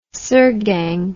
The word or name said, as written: sirgang